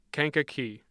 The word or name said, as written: Kankakee